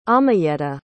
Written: amayeta

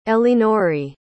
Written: elinore

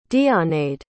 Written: deonaid

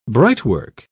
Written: brightwork

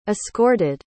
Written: escorted

